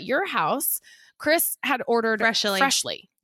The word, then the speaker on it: Freshly: It's just Freshly, like the word.